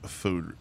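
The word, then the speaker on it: foudre: It's their try at a lager, or their, you know, the greenhouse spelt lager conditioned on American oak fooder. It's spelled foudry, but it's fooder, and I know that.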